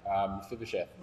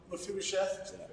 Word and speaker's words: Mephibosheth: Mephibosheth. – Mephibosheth, that's the one.